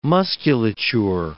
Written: musculature

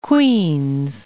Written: Queens

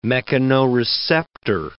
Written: mechanoreceptor

